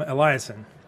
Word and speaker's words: Eliassen: I've got Allen Cordrey and Will Atwood, both from Eliassen.